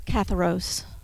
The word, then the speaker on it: katharos: Katharos, or katharos, is the way it's pronounced in the Greek, and it literally means to be clean or to be pure, okay?